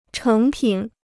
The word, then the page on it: 成品: chéng pǐn